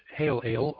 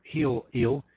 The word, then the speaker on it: Helel: spelled H-E-L-E-L, pronounced Hail-Ail or Heel-Eel.